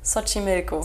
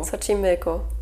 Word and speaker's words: Xochimilco: Sochi Milko. Sochi Milko, genau.